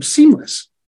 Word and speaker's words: CMLS: So he was going to call it CMLS, chronic mononucleosis-like syndrome, CMLS. That's really not a bad name, seamless. You can pronounce that.